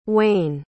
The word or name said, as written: uaine